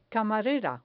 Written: camarera

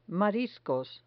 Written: mariscos

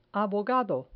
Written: abogado

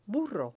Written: burro

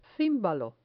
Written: cimbalo

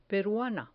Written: peruana

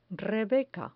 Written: rebeca

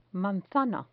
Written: manzana

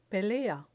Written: pelea

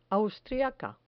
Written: austriaca

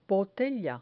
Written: botella

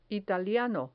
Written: italiano

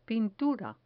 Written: pintura

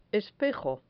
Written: espejo

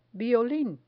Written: violin

